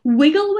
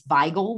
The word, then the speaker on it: Wigle: If you really want to pronounce it correctly, it's Weigel whiskey.